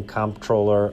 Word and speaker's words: comptroller: My name is Keith Narica, and for most of 2017, I was the acting comptroller of the currency. – You're saying the P. – I'm saying the P and the M. – Narica says he never even considered saying controller because the word is spelled C-O-M-P-T-R-O-L-L-E-R.